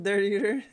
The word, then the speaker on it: Dirteater: Ryan Dirt Eater，Ryan Dirt Eater，好吧，这位选手呢叫做 Ryan Dirt Eater。好，那这个 Ryan Dirt Eater 呢，他是一个骑牛大赛的选手。